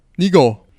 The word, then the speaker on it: Nygaard: Brian Nigo. How you doing, Brian? – Very well, thank you. That was actually probably the best pronunciation. I don't even think Rob Hatch could produce a pronunciation like that.